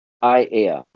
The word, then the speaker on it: Aiea: How do you say the city name? Aiea? – Aiea. – Aiea. – All vowels.